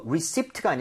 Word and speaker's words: receipt: 이거 그나저나 영수증 할때 이거 receipt가 아니라 receipt입니다. P는 묵음입니다.